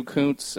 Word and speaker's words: Kuncz: Kuntz, and it's spelled K-U-N-C-Z, so you can imagine where they went with mine.